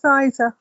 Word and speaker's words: Eiza: Well, it's spelled E-I-Z-A. How would you pronounce it? – Yeah, it's Iza. Okay. – There's no L in there.